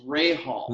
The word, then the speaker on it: Rahal: It's Rahal.